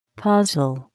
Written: puzzle

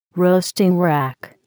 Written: roasting rack

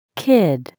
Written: kid

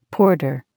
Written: porter

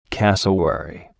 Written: cassowary

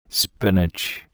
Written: spinach

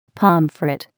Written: pomfret

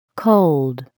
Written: cold

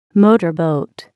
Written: motorboat

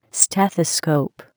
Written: stethoscope

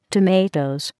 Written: tomatoes

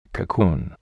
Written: cocoon

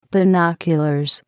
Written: binoculars

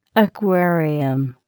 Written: aquarium

